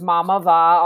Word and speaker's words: Mamava: Yeah, so it's actually pronounced Mamava, although Mom. – people pronounce it all different ways.